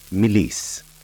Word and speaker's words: Mélisse: Lemon balm in French is pronounced Mélisse and spelt la, L-A, Mélisse, M-E-L-I-S-S-E.